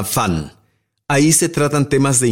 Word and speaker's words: VAN: La redacción aclara que se pronuncia como la palabra inglesa Fan o como en Ludwig van Beethoven.